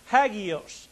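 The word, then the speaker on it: hagios (ἅγιος): The Greek word is pronounced hagios.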